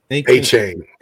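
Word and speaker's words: Achane: A Kane, is it pronounced a Kane? A Kane? – A Chain? – A Chain. – A Chain, yeah.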